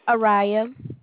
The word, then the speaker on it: Ariah: Araya. – Oh, how you spell it? – A-R-I-A-H.